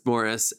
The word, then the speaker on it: Maurice: Morris is spelt exactly the same way that you would spell Maurice. But it's Morris.